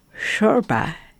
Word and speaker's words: shorba: As we head into the winter months, shorba, as it's pronounced.